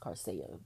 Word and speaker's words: Coursera: I kept pronouncing it as Coursera, but I was told it's pronounced Coursera.